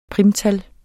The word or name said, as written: primtal